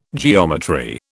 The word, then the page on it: geometry: British English